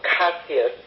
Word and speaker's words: Cássia: J or G, as they pronounce it in Brazil, Cássia, C-A-S-S-I-A.